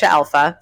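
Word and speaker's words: Alfa: All of my social media and my website are all just my name, Aisha Alpha, spelled with an F, not a PH in the alpha part.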